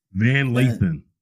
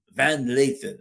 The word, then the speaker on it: Van Lathan: So that's what Van Halen, did I say his name right? – Van Lathan. – Van Lathan. Remember right? – Just call him Van.